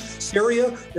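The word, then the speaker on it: Ceria: Saria, pronunciation, it rhymes with area. – All right. – So some people, they wonder, how do you say that weird word? Rhymes with area. We got that name from Ceres, the Roman goddess of agriculture.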